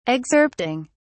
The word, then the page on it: excerpting: British English